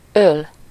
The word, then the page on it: fathom: /ˈfæð.əm/